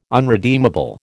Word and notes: unredeemable: American English

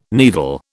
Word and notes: needle: British English

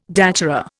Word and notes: datura: Indian

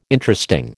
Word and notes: Interesting: American English

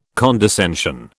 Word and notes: condescension: British English

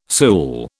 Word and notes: sool: British English

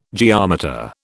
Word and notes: geometer: British English

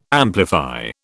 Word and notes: amplify: British English

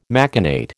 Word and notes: machinate: American English

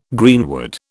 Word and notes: greenwood: British English

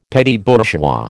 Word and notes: petit bourgeois: American English

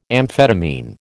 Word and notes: amphetamine: American English

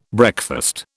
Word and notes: breakfast: British English

